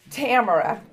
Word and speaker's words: Tamara: By the way, my name is Tamara Wales. Tamara, it rhymes with camera, okay?